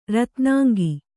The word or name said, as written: ರತ್ನಾಂಗಿ